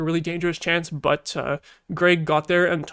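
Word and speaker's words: Greig: Gregg, yes, I pronounced it right. That I feel like I almost said Greek again.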